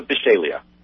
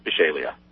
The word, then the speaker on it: Bishalia: Bishalia. – Bishalia. I see. Interesting name. – Bish, kind of like fish. Bish, and then a long A, and then Leah. Bishalia.